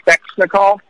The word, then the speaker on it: Svechnikov: They have that 19-year-old Svechnikov. I pronounced his name right.